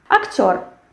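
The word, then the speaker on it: актёр: The same thing. We have the stress which falls on the last syllable. And we have this firm sound r. Yes, актер, not actor. And not r. We have r.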